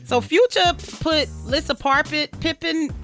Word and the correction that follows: Larsa Pippen: How you say it? – Larsa Pippen.